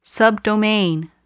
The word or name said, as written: subdomain